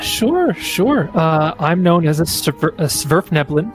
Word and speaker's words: Svirfneblin: Svirfneblin. – Svirfneblin. – One more time. – It's Svirfneblin.